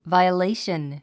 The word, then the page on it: violation: vàiəléiʃən; バイオレイション